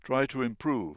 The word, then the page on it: prove: British English